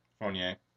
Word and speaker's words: Fournier: Fournier.